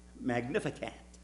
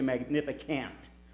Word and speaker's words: Magnificat: I can never say it right. Magnificat. I always want to say Magnificant.